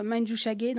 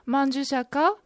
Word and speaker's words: Manjushaka: Et pourquoi la chanteuse a choisi Manjushaka au lieu de dire Manjushage dans cette chanson? – Au niveau de la prononciation, Manjushaka est plus proche de la prononciation de sanskrit. Et puis, ka sonne mieux que ge dans cette chanson, n'est-ce pas?